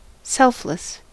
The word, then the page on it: selfless: American English; [ˈsɛlf.lɪs]